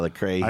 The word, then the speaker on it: Lecrae: All right, well, and that is Lecrae. Is what am I saying that right? – Yeah, Lecrae.